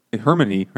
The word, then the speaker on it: Hermione: Hermione, right? – He keeps saying, no, it's like this.